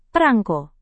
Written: perangko

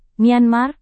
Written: myanmar

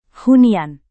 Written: hunian

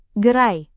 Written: gerai

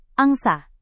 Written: angsa

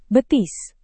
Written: betis

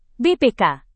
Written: bpk

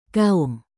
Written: kaum

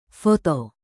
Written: foto

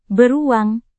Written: beruang

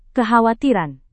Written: kekhawatiran

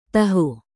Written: tahu

